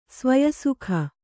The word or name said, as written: ಸ್ವಯಸುಖ